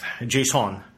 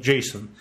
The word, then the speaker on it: JSON: Вот, а, ну, JSON. То есть он ударение просто меняет. – Да. Я лично предлагаю вообще называть JSON. – Не, ну как бы S-O-N, оно похоже на как бы слово sun.